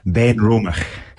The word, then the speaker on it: Benromach: So Benromach. – Benromach. – Yes. – I got that guy. Benromach.